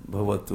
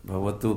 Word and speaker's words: भवतु: वाह, जगन्नाथ स्वामी नयन पथगामी, क्या आगे क्या फिर आगे, भवतु में, ओके भवतु कहना चाहिए, भवतु में, भवा नहीं भवातु में, वो गलत है प्रोनाउंसिएशन की ओर भी,